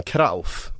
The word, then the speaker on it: Cruyff: men alltså det är många, alltså, han heter ju till exempel inte Johan Cruyff, han heter Johan Krauf, om man ska uttala det på holländska.